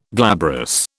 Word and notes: glabrous: British English

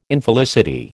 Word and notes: Infelicity: American English

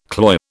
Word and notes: cloy: British English

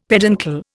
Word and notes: peduncle: Indian

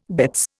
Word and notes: bitts: Indian

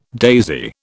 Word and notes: daisy: British English

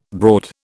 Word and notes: broad: British English

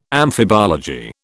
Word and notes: amphibology: British English

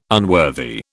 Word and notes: unworthy: British English

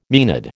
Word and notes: maenad: American English

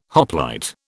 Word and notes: hoplite: British English

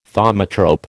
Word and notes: thaumatrope: American English

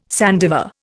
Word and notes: sandiver: Indian

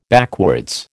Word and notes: backwards: American English

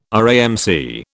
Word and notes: RAMC: British English

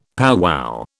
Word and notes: powwow: British English

